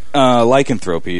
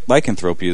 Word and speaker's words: lycanthropy: It's actually lycanthropy. – Lycanthropy is what I was going after. – It's pronounced lycanthropy. – It's lycanthropy. When in doubt, here's the rule you should follow. When in doubt, put the accent on the semi-penultimate syllable. Lycanthropy.